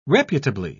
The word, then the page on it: reputably: British English